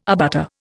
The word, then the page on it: abutter: Indian